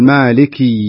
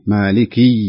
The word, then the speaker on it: مالك: أيضا في هناك بعض الناس يبالغ في كسرة الكاف في مالك يوم الدين، فيقول مالك يوم الدين، مالك يوم الدين، فينطقها ياء، والصحيح أن تكون كسرة فقط، كسرة مالك